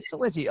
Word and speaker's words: Silentium: Yeah, well, I mean, of course, uh, we're talking about your band uh, Silentium. Is that how you pronounce it? You guys pronounce yes. it that way? Silentium.